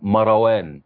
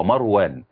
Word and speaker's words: مروان: الناس اللي بتسمي أسماء وتنطقها غلط يقول لك ابن ايه عندي رزقتي، بيقول لك مروان، لا هو خطأ، مروان خطأ، هو مروان وليس مروان.